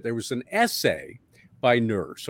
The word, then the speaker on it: Nourse: It's pronounced Nurse, but spelled N-O-U-R-S-E.